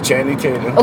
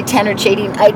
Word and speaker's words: Channing Tatum: Yeah, Channing Tatum. – Okay, I usually said his name wrong. I would say Tanner Chading.